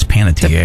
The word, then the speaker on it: Panettiere: Panettiere. – Hayden Panettiere. – I said that, Panettiere. – You said A on the end. It's Panettiere.